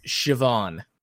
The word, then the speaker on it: Siobhan: This is an Irish name, and it's pronounced Siobhan.